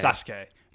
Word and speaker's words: Sasuke: Sasuke. There's a U in the middle, but it's not pronounced Sasuke.